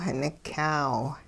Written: nikyaaw

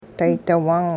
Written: daydi-wung

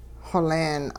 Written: xoleen

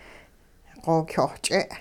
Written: xokyoh-ch'e

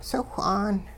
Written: siwh'aan

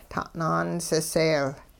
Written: ta'naan siseel

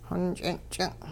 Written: xonch'inch'in

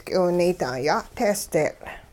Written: k'iwunaydaa-ya'tehsdeetL'

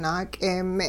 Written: naak'eeme'

